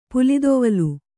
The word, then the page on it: ಪುಲಿದೊವಲ್: pulidoval